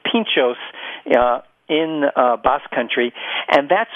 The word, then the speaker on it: pintxos: Right, and we can spell it Pinchos, P-I-N-T-X-O-S, Pinchos.